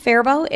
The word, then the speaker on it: Faribault: It's pronounced Faribault. It's spelt Faribault.